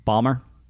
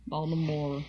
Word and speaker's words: Baltimore: There is a T in that word somewhere that I stopped pronouncing years ago. – Balmer? – Baltimore.